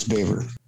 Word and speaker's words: Boever: B-O-E-V-E-R? – I believe it's Baver. – He should really spell it with an A if he wants that to be the case. – Baver the Saver? – Yeah, you're correct here. The pronunciation Baver.